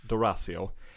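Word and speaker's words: D'Orazio: En kvinna som heter Valerie nu ska jag uttala efternamnet, ett Dosario, eller Dorazio, förlåt mig, Valerie Dorazio.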